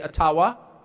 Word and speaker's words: Ottawa: It cannot be taken from you, no matter who is in Ottawa, or as we say, Ottawa, which, by the way, is the correct pronunciation, according to my friends in the Ojibwa community.